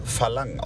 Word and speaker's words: Farang: Der da heißt Farang. Wobei uns gesagt wurde, es wird im Thailändischen Phalang ausgesprochen, das R.